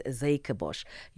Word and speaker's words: Zuikerbosch: Thank you, Lucky, for correcting my pronunciation. Zuikerbosch, so you say. Okay, it is Zuikerbosch.